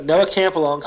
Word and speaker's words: Noah Campalong: So Noah Campalong, sorry if I said that wrong. Shout out, friend of the show. – You said it right.